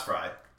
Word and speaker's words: Frey: No, it's Frey. Frey. It's Frey. – Yeah, it's Frey.